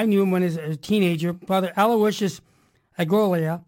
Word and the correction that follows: Ellacuria: How do I pronounce the last name? – Elecoria.